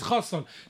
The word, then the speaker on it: Chassan: Chatan, Chatan. No, but he says Chassan.